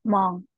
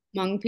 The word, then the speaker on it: Hmong: Do you say it Hmong? – Yeah, Hmong. – Hmong people. Okay. And I looked it up. Yeah. It's spelled H-M-O-N-G.